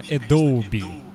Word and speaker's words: Adobe: Правильно вообще, конечно, Adobe. Но Adobe. Понятно, что ни один нормальный человек так произносить название это не будет. – Да, Adobe. Adobe Photoshop. Обычно я так произношу.